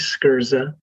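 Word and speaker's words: Sgherza: The last name is Skirza. And there's always a story behind a name like that. So here it goes. So that, you know, phonetically it's spelled S-K-E-R-Z-A, but it's S-G-H-E-R-Z-A.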